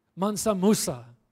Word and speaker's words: Mansa Musa: Yes, Mansa Musa.